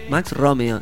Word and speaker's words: Max Romeo: Que, Max Romeo, ¿tiene el acento en la primera voz? – Max Romeo. – Romeo. – Sí, Max Romeo.